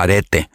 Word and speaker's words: Arete: estudiantes debajo del mandato de esta mujer de areta. Algunos pronuncian arete. Yo, yo voy a estar intercambiando arete o areta.